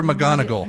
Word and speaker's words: McGonagall: Professor McGonagall.